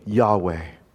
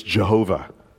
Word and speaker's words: Yahweh: Yahweh. Or you could pronounce it in English, Jehovah. That's fine too.